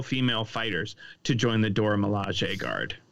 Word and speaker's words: Dora Milaje: The Dora Milaje. How do you say it? Sorry. – Yeah, Dora Milaje.